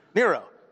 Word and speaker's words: Nero: Starts with an N. Rhymes with zero. Okay? Nero.